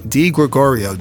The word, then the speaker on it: Di Gregorio: Di Gregorio, D-I-G-R-E-G-O-R-I-O. And I always tell people the way to learn how to pronounce it, the letter, the name, the cookie, Di Gregorio.